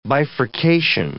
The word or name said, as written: bifurcation